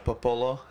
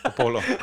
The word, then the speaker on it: Popolo: Alika's going to listen to this and be like, you didn't even pronounce it right. – Yeah. Dom's mom said Popolo. – Popolo. That's a Hawaiian thing.